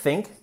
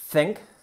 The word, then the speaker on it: think: Tenemos la pronunciación de think, que no es con i, que no es think, ¿vale? Think. Sencillo, y el pasado, que es irregular, entonces, pero sería de esta forma, think, thought, I thought, yo pensé, ¿vale?